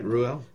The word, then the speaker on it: Reuel: Yeah, that's right, Reuel.